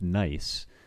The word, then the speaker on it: gneiss: there's largely gneiss, but it was for the most part pronounced Nice. at least that was how we were told to pronounce it in interpreting it outdoors.